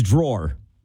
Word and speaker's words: drawer: But it's drawer. – Let me go in the junk drawer. – Right. – No way. – That's exactly how you say it. I don't say it that way, but I know that I have been told that I say it wrong.